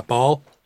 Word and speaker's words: Baal: Yeah, Baal.